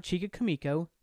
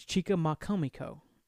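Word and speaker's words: Chicamacomico: It's not Chica Kamiko. It's Chica Makamiko. Chica Makamiko. All one word.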